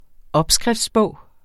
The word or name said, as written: opskriftsbog